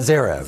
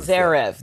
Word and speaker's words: Zverev: Zverev. – Zverev.